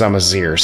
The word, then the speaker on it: Amazigh: It's spelled Amazigh, but the pronunciation is Amazir, so I have no idea how to say this properly.